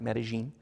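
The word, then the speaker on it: Medellín: They pronounce it Medellin.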